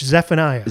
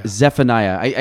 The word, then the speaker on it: Zephaniah: It's with a PH, Zephaniah. – Zephaniah. I know it's always a Z, but I can never pronounce it.